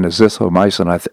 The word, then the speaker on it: azithromycin: The use of the drug, often paired with azithromycin, I'll say that again, azithromycin, I put the emphasis on the wrong syllable there, anyhow, has not yet been proven in clinical trials to be effective against the disease.